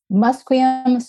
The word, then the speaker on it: Musqueam: Musqueam, Squamish, and Tsleil-Waututh.